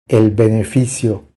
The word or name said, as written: El beneficio